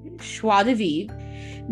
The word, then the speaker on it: Schwa DeVive: Her name is Schwa DeVive. – How do you spell that? – Schwa is S-C-H-W-A. – Yeah. – DeVive, D-E-V-I-V-R-E. Schwa DeVive.